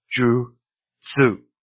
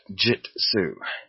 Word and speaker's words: jutsu: And it is always pronounced with hard U's. Ju. Tsu, and not jut, and then su. In Japanese, the T falls to the second syllable. It is ju, tsu, not jut, su. Jutsu, jutsu, nin, ju, tsu, not jit, su.